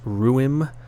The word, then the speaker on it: Ruim: Um, but it's a two piece band, been going since 2020, and it's, I believe, just pronounced Ruim, R U I M.